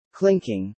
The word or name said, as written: clinking